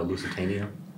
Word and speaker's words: Lusitania: Sinking the Lusitania.